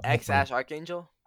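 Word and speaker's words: X Æ A-12: But Elon said the X is just X. The AE is Ash, because that's how you pronounce it in Greek. – Yeah, that's the one I heard. – And then A12 stands for Archangel, which was the Archangel 12.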